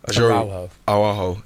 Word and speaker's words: Araujo: Araujo to Liverpool.